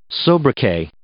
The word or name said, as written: sobriquet